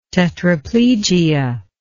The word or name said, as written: tetraplegia